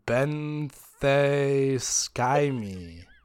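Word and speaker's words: Benthesikyme: And Poseidon rescued it and gave it to one of his daughters, which I'll attempt to pronounce. It is Benthesky-me. – Nailed it.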